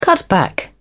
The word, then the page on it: cutback: British English